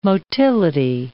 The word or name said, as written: motility